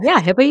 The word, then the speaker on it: Hebba: Hebba Youssef.